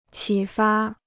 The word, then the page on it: 启发: qǐ fā